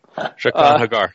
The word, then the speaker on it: Jaqen H'ghar: She's talking with Jaqen H'ghar. Everybody can make fun of me if I said that wrong. But I tried this time. – Jaqen H'ghar, yes.